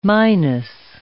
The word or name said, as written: minus